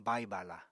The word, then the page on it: bai-bala: Apatani